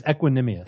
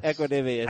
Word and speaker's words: Equanimeous: I think it's Equanimeous. – Equanimeous. – I think that's it.